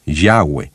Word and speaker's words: Yahweh: y el nombre parece mejor ser pronunciado Yahweh.